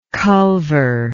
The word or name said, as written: culver